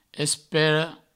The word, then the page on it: espera: provençal; espero